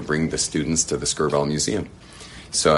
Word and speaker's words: Skirball: Skirball? Two syllables, Skirball. – Yeah, Skirball.